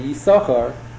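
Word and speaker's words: Yisachar (יששכר): A strange, startling thing, in most shuls, when the Baal Koreh reads Kriyas HaTorah, so we normally pronounce the name Yisachar, you only pronounce one shin of the two Yisachars.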